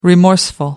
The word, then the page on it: remorseful: American English